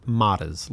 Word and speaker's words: Mottaz: Matas. Like, Natas.